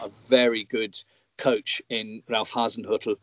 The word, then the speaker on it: Ralf Hasenhüttl: Congratulations, by the way, on being able to pronounce his name way better than 90% of people that work for the English Premier League. A lot of my colleagues so insist on calling him Ralf Hasenhüttl, which drives me, of course, as a German speaker, absolutely bonkers.